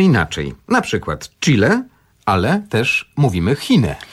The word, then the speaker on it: Chile: Chile to jest nazwa zapożyczona wprost z języka hiszpańskiego, a właściwie zapożyczona, znaczy do hiszpańskiego trafiła od Indian, z jednego z języków Indian, którzy stworzyli tę nazwę. I tutaj zapisujemy to CH, wymawiamy chi, to jest taki dźwięk, którego właściwie w języku polskim nie ma. On się pojawia tylko w niektórych wyrazach zapożyczonych, tak jak właśnie chile, czy na przykład chipsy, czy chill out.